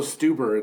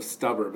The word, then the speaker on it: Stuber: It's also Stuber instead of Stubber, but that's a hard one to pronounce.